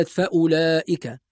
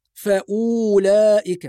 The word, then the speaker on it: فأولئك: بعض الناس لما ينظر إلى هذه الكلمة ويرى حرف الواو بعد الهمزة المضمومة يظن أن الواو تنطق فيقول فأولئك، وهذا خطأ. فهذه الواو ثابتة في الرسم فقط لا تنطق، وعلامة عدم نطق الواو الدائرة المرسومة فوق حرف الواو، هذه الدائرة معناها أن الواو ترسم ولا تنطق، فنقول فأولئك هم العادون.